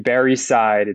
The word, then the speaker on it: Barre: with this other guy named Barre Seid. It's spelled strange, strangely. It's B A R R E,